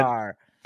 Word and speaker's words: Jarre: Cosmatos and written by Kevin Jarre, I think is how you pronounce Kevin it from Jarre. The things I've watched. Yeah. – Yeah. Kevin yeah, Jarre.